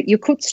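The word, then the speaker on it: Yokuts: And this pictograph it was created by the Yakuts tribe. Is that how you pronounce the name? – Right. That is kind of the language group.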